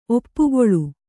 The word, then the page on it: ಒಪ್ಪುಗೊಳು: oppugoḷu